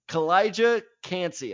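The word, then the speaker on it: Calijah Kancey: Kalijah Kancey.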